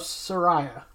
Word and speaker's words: Saraya: All right, so AEW did recently see the debut of Saraya. I said that right. – Yes.